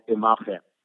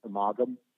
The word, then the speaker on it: Imagem: It was a Dutch-based company, and the company's the proper pronunciation, which, you know, I don't have a European accent, so forgive me. It's it was called Imagen. It's spelled I-M-A-G-E-M, like, so image with the letter M at the end.